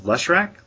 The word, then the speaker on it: Leshrac: Chaz talks about black cards in Commander, including, I'm going to butcher this, it's Herald of Leshrac? – Bam! Spot on.